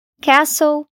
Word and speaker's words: castle: Palavras terminadas em STEN ou STLE têm o T silencioso. Alguns exemplos disso são as palavras fasten, apertar, listen, ouvir, castle, castelo e whistle, apito.